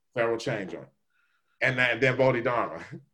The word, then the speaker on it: Bodhidharma: Bodhidharma.